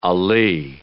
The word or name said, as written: alee